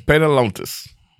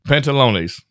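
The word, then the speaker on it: Adios Pantalones: Adios, Pantalones. I said that wrong. Pantalones.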